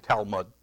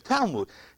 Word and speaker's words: Talmud: T-A-L-M-U-D. And I was calling it Talmud. And Amy, my daughter, they're not my daughter-in-laws, they're my daughter. She asked how to pronounce that. And it's Talmud, M-O-O-D, I guess.